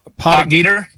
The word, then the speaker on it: Potgieter: Aldrich Potig, how do you say it? – Potgieter and Stefan Jaeger.